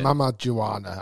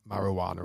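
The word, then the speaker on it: Mamajuana: Is it Mam Mam Juara or something? – Yeah, Mam Mam Juana. I got the name wrong. It's not marijuana or whatever I said.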